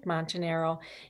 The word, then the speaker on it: Montanaro: But it's Rosa Smith Montanaro if you wanted to look up my name. – Okay. You didn't roll your R there, Rosa. It's your own name.